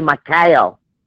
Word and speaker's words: Mikhail: And like Michael's proper name would be Mikhail. And they're almost always with the I-A-L at the end.